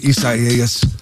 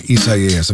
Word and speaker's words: Isaias: Isaias. Got to say that right. I keep saying Isaias. I'm looking for a reason to say that today. Isaias is wrecking their wedding plans.